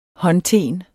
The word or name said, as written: håndten